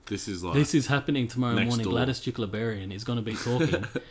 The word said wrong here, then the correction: Berejiklian: Berejiklian. – Bro, did I say Jukleberian?